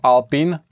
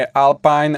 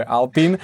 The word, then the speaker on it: Alpine: Ja dúfam to, že v týme Alpine, hovorí sa to Alpine, nie Alpine ani Alpine, je to francúzske slovo, takže sa to vyslovuje Alpine, aby sme všetci boli v obraze.